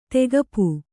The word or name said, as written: ತೆಗೆಪು